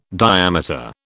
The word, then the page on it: diameter: British English